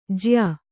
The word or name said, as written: ಜ್ಯಾ